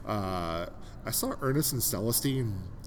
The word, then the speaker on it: Celestine: How do you say it? – Celestine.